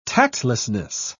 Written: tactlessness

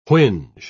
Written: whinge

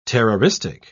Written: terroristic

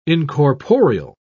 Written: incorporeal

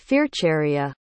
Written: fearcharia

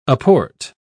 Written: aport

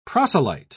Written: proselyte

